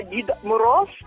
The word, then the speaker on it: Дід Мороз: Algo parecido a Papá Noel, algo parecido a Santa Claus, se llama Дід Мороз. Se pronuncia así en ucraniano y también viene con su nieta,